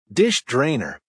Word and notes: dish drainer: American English

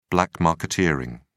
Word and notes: black marketeering: British English

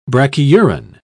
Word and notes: brachyuran: British English

